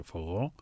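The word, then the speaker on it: forró: Και πάμε τώρα βορειοανατολικά στη Βραζιλία να ακούσουμε ένα κομμάτι φοχό, μουσική φορό με δύο ρο, προφέρεται φογό, και ένα σούπερ χιτ που χορεύεται πολύ ακόμα και στα βραζιλιάνικα στέκια και στα Latin χορευτικά κλαμπ και στην Ελλάδα.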